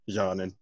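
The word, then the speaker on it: Džanan: Their offer is Spencer Dinwiddie, Jared Allen, Dizanon. Am I pronouncing that right? – Jonan. – Okay, Jonan. John and Musa, and a protected first round pick in exchange for Drew Holiday.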